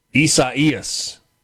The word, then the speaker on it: Isaias: Right now, the timeline, somebody said that it's, Caleb in Starkville says that this is pronounced Isaias, the Spanish and Portuguese pronunciation for Isaiah.